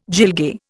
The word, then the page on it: gilgai: Indian